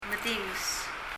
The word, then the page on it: medinges: [mɛðíŋɛs]